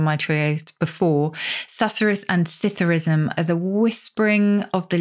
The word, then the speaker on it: psithurism: The second is one I think a lot of people will have heard of, and that's Citherism with a silent P. Citherism. – The P is silent, as in swimming pool. How do you spell this word? – P-S-I-T-H-U-R-I-S-M.